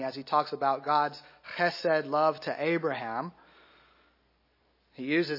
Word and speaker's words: chesed: It's chesed. It uses one of those great Hebrew guttural sounds. If you're not saying it right, you should be hawking a loogie as you say it, right?